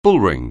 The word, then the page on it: bullring: anglais